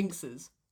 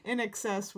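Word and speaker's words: INXS: Or Inkses. – Inkses. No, that would be Inses. – Oh. – Because an X would be pronounced as a C in the middle of a word.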